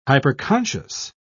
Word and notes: hyperconscious: British English

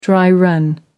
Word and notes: dry-run: American English

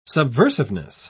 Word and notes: subversiveness: British English

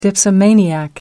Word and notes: dipsomaniac: American English

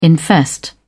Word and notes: infest: British English

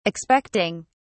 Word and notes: expecting: British English

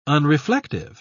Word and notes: unreflective: British English

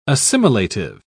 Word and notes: assimilative: British English

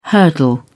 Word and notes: hurdle: British English

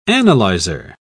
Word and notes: analyzer: British English

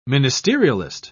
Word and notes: ministerialist: British English

